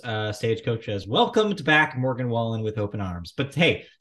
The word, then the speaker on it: Morgan Wallen: And then for the first time headlining Stagecoach in 2024 is Morgan Wallen. Yeah, you gotta say his name like that.